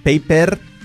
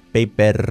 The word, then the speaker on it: paper: Escrito paper, paper, con R española, ¿lo veis la diferencia? Fijaos, paper, lengua para atrás, paper, paper, paper. Además es tan fuerte que prácticamente se come a la vocal. Paper, paper. ¿Cómo decimos la palabra coche en inglés? Car, C-A-R.